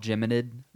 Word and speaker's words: Geminid: It's called Geminid Meteor Shower.